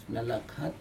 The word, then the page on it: nalak hat: Aneityum